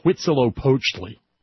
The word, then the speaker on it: Huitzilopochtli: like Huitzilopochtli? – That how you pronounce that?